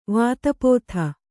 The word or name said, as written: ವಾತಾಪ್ತ